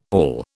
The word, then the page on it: all: British English